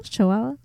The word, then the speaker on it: Chihuahua: I have got on April 10th in 2009, the residents of La Junta in the Mexican state of Chihuahua. Is that how it's said, Chantel? – Mm-hmm. – Chihuahua.